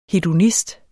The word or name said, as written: hedonist